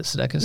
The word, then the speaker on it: Sudeikis: Uh, the statement was so Ted Lasso or the um, Jason uh, Sudeikis. Is it Sudeikis? – Mm-hmm. – Sudeikis. Sudeikis. – Sudeikis.